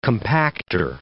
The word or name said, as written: compacter